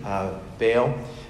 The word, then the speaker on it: Baal: Baal in particular, if you want to do a Hebrew pronunciation, it's Baal.